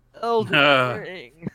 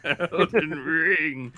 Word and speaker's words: Elden Ring: Elden Ring. – Elden Ring.